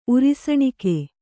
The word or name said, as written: ಉರಿಸಣಿಕೆ